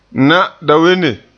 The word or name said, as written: na'adawenne